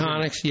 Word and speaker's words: Protonix: Protonix, is that how you pronounce it? – Protonix, yes.